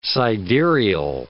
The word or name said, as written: sidereal